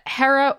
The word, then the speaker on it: Hera: So yeah, Hera, or it could also be pronounced Hera, both are correct.